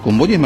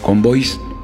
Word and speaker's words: convoyes: ¿Convoyes, me dicen? – Convoys. – Convoyes, señor. – Y así se escribe.